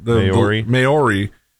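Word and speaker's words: Māori: Maori.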